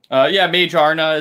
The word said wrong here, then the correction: Magearna: is an artificial Pokemon. That's a really good point. Or Magearna, excuse me.